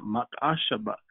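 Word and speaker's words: mach-ash-abah (מחשבה): But the word thoughts, it comes from the Hebrew mach-ash-abah. That's how you say it, mach-ash-abah.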